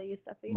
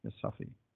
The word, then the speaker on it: Yousafzai: Malala Yousafzai. – Malala Yousafzai.